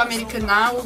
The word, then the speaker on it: Americanah: Porque a personagem principal também diz que o americana, a última sílaba é pronunciada assim, extensamente.